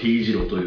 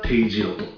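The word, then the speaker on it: T字路: ていうのはあんまりしたくないんですけども、滋賀県で結構悲惨なねニュースがありまして、まあいわゆるその定時路、あれ T 字路じゃないんですよ、定時路ね、漢字の定なんですけれども、まあ、ほとんどのアナウンサーが T 字路という中、関西テレビの豊田アナウンサーだけは定時路とちゃんと発音されてまして、ちょっと感動を覚えましたが、まあその話はどうでもよくて、いやもう本当に悲惨な事故でしたね。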